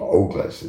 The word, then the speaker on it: Ogles: I think he pronounces his name Ogles. It's O-G-L-E-S.